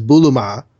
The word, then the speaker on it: Bulma: It's a pun on the English word bloomers, pronounced Japanese-ishly as Bulma.